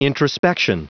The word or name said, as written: introspection